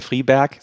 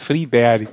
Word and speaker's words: Friberg: Das ist nicht der Friberg, sondern der Friberg.